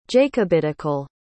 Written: jacobitical

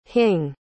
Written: hing

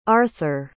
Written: arther

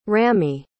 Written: Ramey

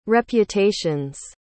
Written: reputations

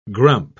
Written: grump

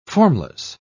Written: formless

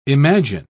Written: imagine

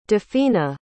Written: dafina

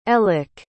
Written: elick

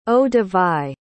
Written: eau-de-vie

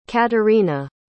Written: caterina

